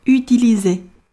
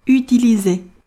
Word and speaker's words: utiliser: 最后一个词 utiliser。当一个单词里既有 u 又有 e 的时候，这个单词往往不太好读，所以要着重引起我们的注意。 u 是要把嘴使劲的往出凸，e 尽量的咧开，所以这个词读作 utiliser utiliser。